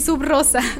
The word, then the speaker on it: Subrosa: Este, me da risa porque de repente me dicen, ¿se dice Subrosa o se dice? Y yo, no, no, no, sí, Subrosa. Pero así tal cual se escribe. S-U-B,